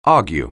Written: argue

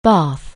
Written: bated

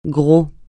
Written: gros

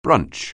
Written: brunch

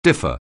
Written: differ

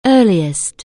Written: early

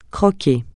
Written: croquer

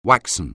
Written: waxen

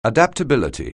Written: adaptability

